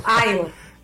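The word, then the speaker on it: Hayla: O H é mudo. Ayla.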